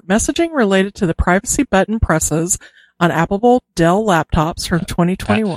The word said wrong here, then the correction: applicable: Yeah, applicable.